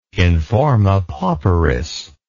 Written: in forma pauperis

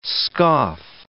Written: scoff

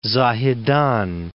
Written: Zahedan

